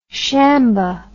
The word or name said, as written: shamba